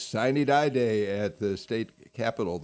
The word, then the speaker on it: Sine die: Murphy was speaker, but you said it's pronounced Sine Die, y'all.